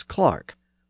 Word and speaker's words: clerk: American English does actually have the pronunciation of clerk as Clark, but it's only retained as a name, like Dick Clark or Clark Kent.